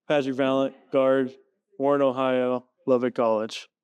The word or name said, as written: Patrick Valent